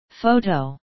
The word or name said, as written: photo